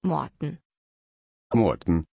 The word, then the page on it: Morton: German